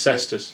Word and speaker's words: Cestus: Celestus Ram, however you pronounce Cestus. – It. That's it. – Cestus Assault Ram.